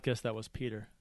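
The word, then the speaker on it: Pyotr: I would have never guessed Peter. – What it is? What it is? The O is silent and the E is non-existent. – Right. Yeah, and the Y sounds like an E.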